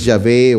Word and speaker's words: Yahvé: para no traducirlos si es Yahvé o Jehová. Aquí dice eh, YHVH, pero eh, lo voy a pronunciar Yahvé porque parece que esa es la pronunciación más acercada a, la realidad.